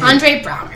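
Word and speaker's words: Andre Braugher: And you know, so let me, if you haven't watched the show, Holt is Andrea Brower. How do you say his name? – Andre Brower. – Andre. Okay, I was really close. I just gave him a feminine extra syllable.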